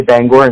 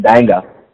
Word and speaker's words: Bangor: So you can always tell somebody's been to Maine because they'll say Bangor instead of Banga.